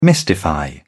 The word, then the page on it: mystify: British English